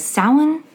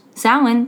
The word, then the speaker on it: Samhain: Samhain is also pronounced Samhain, but it's spelled S-A-M-H-A-I-N. It's not Samhain. It's Samhain.